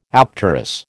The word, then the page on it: apterous: American English